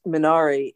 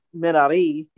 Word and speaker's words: Minari: It looks like it's pronounced Minari. It's actually Minari in Korean.